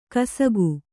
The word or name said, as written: ಕಸಕು